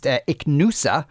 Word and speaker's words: Ichnusa: On that beer that was being asked about, someone texted in to say it's pronounced Ichnusa.